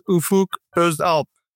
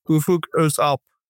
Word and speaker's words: Ufuk Özalp: My full name is Ufuk Özalp. We have a different letter, like pronunciation different. Mm-hmm. So my last name is the kind of like starting to like kind of the O and with dot.